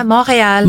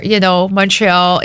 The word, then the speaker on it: Montreal: Montreal. – Montreal. – Montreal. – Yes. – Is it that's how you pronounce it? Yeah. – Yeah, Montreal. – Montreal. – Montreal in English.